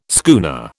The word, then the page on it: schooner: British English